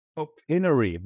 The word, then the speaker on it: Opinary: OpenAri, like opinion.